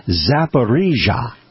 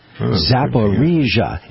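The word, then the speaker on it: Zaporizhzhia: Yes, I'm now going to display my acuity at pronouncing that word. It's Zaporizhia. Zaporizhia.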